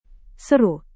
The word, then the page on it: seru: indonesian